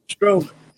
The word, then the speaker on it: Strowman: Stroheim. What? How do you say his name? – Strowman.